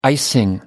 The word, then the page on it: icing: British English